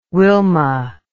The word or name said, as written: Wilma